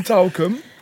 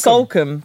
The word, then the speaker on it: Salcombe: Yeah, you're from Talcum. Is that how you say it? – No, Salcombe. Salcombe.